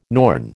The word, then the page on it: Norn: American English